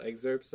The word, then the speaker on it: excerpts: It just comes up every now and then, so I've been adding little excerpts of what's been going on, but it's realistically... – You know, most people don't pronounce the P in it, right? – What?